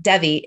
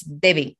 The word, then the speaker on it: Devi: because it's not Debbie, it's Debbie. It's Debbie. Like saying a B through a V.